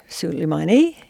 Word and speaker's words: Soleimani: Soleimani. Soleimani in Iranian pronunciation,